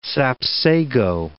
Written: sapsago

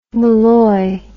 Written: Molloy

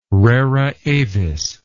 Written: rara avis

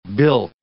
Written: bilk

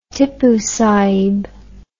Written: Tippoo Sahib

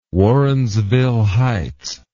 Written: Warrensville Heights

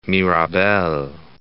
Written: Mirabel

